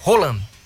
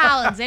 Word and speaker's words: Haaland: O verdadeiro nome, inclusive, ele tá corrigindo muitos profissionais aí da imprensa pelo mundo afora, que o nome dele é Haaland. – Ah, todo mundo fala Haaland, né? – Por causa da pronúncia, né? Meio esquisita. – Sim. Mas enfim, é Haaland. – É Haaland, ele não tá aqui.